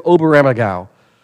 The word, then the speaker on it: Oberammergau: So it's the land above the river, The Gospel According to Oberammergau.